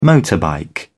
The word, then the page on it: motorbike: British English